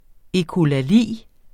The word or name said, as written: ekkolali